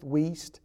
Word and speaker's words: Wuest: WEEST. And WEEST is spelled W-U-E-S-T, so it's a unique last name. It's Kenneth WEEST, and he is a Greek scholar, and has produced some amazing Greek resources.